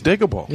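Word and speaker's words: Digable: You got your Arrested Development, you got Digital Planets, Digible Planets. – I thought it was Diggable. – Yeah, it's Diggable, dummy.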